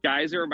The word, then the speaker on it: Geiser: Sure, you can follow me on X at Real Chase Geyser. My last name is spelled G-E-I-S-E-R.